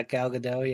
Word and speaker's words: Gal Gadot: Is that how you say her name? Is Wonder Woman? Yeah, Gal Gadot.